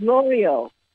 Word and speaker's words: Lorio: Spell it oh, my birth name is Lorio, L-O-R-I-O. – Oh, sorry, it's L-O-R-I-O.